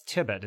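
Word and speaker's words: Tibbott: I got to say, for me, the highlight was, it's weird, the highlight was right at the beginning, but I thought Seth Tibbett, is that correct? Did I get his name right? – You did, yeah.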